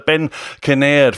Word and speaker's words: Ben Kinnaird: We have joined us for the first time ever, Mr. Ben Kinnaird from Rather Inventive.